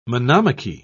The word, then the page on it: monomachy: British English